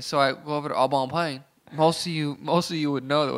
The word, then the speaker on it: Au Bon Pain: Au Bon Pain? – Yeah, that's how you say it. Au Bon Pain.